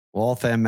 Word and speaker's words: Waltham: Waltham. – Waltham, Mass.